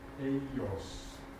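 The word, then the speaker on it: Hygieios (ὑγίειος): Wir beginnen das Wort also mit H vorangestellt, nicht nur vorgestellt, HY, dann Gamma, Jota mit der Betonung, Epsilon Jota, Omikron, Sigma. Hygieios. Hygieios.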